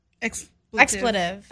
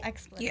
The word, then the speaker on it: expletive: Like as an explicitive. – Expletive. – Expletive. Is that how you say it? – Expletive.